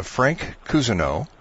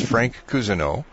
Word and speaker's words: Cousineau: Our guest today is Frank Cousineau.